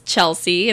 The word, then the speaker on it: Chelsey: uh, but my handle is Chelsey, and that's spelled with a Y, C H E L S E Y, CBS5.